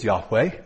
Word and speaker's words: Yahweh: And they'll usually say something like Jehovah, or perhaps they'll come a little closer, but they'll mispronounce it in terms of the Hebrew or the Greek. Well, what is God's name? Is it Yahweh, as most Old Testament scholars agree? Well, it is Yahweh, which is one of His many names in the Old Testament.